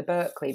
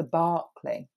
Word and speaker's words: Berkeley: And then the other thing I did, which actually was almost a week previous now, but I went for afternoon tea at, I call it the Berkeley because that's how it's spelt. But I did ask the PR because I went for a free afternoon tea because my friend is the PR there. And it's called the Barclay.